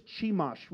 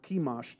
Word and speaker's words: Chemosh: Ruth, the Moabitess, who worshipped Chemosh, we talked about, or Chemosh, depending on how you say it, last week.